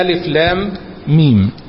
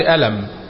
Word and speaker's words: الم: عندنا ألف لام ميم، ديت فواتح الصور بتتنطق بالمسميات اللي موجودة فيها، يعني ما بننطقش ألم، لا هي بتتنطق ألف لام ميم.